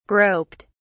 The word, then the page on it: groped: British English